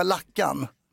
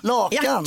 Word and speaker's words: lakan: Du säger inte, du säger inte, jag måste bara gå in och byta lackan. – Lackan? – Lakan.